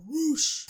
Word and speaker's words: Rush: Next up, it was a grudge match as Rush, or Roosh, as I believe it's pronounced, took on the former Ring of Honor World Champion, Dalton Castle.